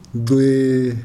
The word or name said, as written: nxwee